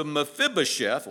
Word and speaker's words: Mephibosheth: So the king said to Ziba, here, all that belongs to Mephibosheth, last time we're going to say his name tonight probably, is yours.